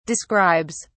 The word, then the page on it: describes: British English